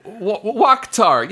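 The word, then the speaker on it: Waaktaar: Waktar, yeah. Just think of it like a Klingon name.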